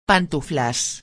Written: pantuflas

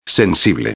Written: sensible

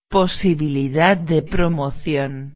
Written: posibilidad de promocion

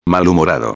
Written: malhumorado

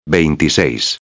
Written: 26

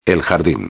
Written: el jardin

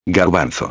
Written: garbanzo

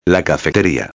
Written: la cafeteria

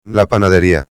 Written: la panaderia

